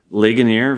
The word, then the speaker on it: Ligonier: It's a town in Pennsylvania, Ligonier. – Yeah, I was just gonna say. Ligonier, very famous Pennsylvania area.